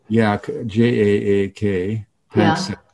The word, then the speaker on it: Jaak Panksepp: Jaak, J-A-A-K, Panksepp, P-A-N-K-S-E-P-P.